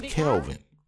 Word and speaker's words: Kelven: My name is Kelven. People pronounce it Calvin, Kevin. I've even heard people say Kelven, which I don't know what type of dyslexia that is.